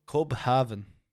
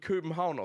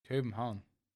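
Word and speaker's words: København: Copenhagen. – Copenhagen. Copenhagen. – Ja, ausgesprochen wird's. Pass auf, pass auf. Ich, ich sehe komplett euren Punkt mit dem V. Nur wird dieses AU, wird Köbenhauen ausgesprochen. Köbenhauen.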